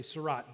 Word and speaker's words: Surratt: And if you know my last name, it's spelled S-U-R-R-A-T-T. And you try to be polite and you say Surratt, but it's not. It's Surratt.